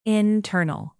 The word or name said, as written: internal